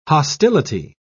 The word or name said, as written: hostility